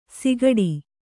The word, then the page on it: ಸಿಗಡಿ: sigaḍi